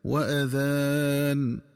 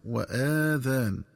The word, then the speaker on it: وأذان: ينبغي التنبه للنطق الصحيح لكلمة وأذان، فتنطق بهمزة مفتوحة دون مد هكذا وأذان، بمعنى وإعلام. ومن الخطأ قراءتها بفتح الهمزة وإشباع فتحتها حتى يتولد منها ألف فتصير وآذان، والآذان جمع أذن.